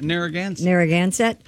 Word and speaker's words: Narragansett: Narragansett Police Department. – Narragansett.